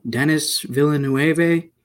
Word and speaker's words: Denis Villeneuve: Denis Villeneuve? – Yeah.